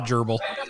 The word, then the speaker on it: gerbil: He did the cherbil for both of them. – The cherub? – Cherub? – Cherbil? The gerbil? – The gerbil.